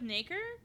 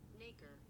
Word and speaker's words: nacre: And your bone can do that with nacre.